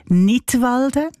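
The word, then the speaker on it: Knidwalden: Unter einem Titel mit einem Wortspiel Nidwalden, ausgesprochen wie der Kanton Nidwalden, aber geschrieben mit einem K vorne dran, so wie beim englischen Wort für Lismen, to knit.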